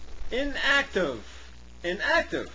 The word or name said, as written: inactive